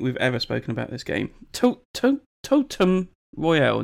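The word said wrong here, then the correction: Tumult Royale: No, that's not how you say it. – Tumult Royale.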